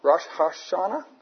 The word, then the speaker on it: Rosh Hashanah: It's also called Rosh Hashanah. Rosh Hashanah? Is that how you say it, Peter? Yeah.